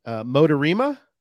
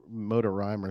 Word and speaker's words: Motorima: So we've got Jamie Motorima. – Yep. – Did I say it right? – That's correct. – I think back in the day I said the Motor Rhyme or something. That didn't sound right. Motorima.